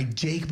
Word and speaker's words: JVKE: Golden Hour by Jake, but it's spelled J-V-K-E.